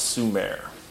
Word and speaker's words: Sumer: S-U-M-M-E-R. This is Sumer. – Okay. – It's spelled Sumer, though? – Yep, S-U-M-M-E-R, but it's pronounced Sumer.